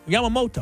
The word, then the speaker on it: Yamamoto: Yamamoto.